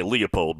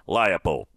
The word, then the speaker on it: Leopold: Number five, Lance Leopold. I've heard people say Leopold because that's what you're used to calling that name. It's, in this case, it's pronounced Leopold.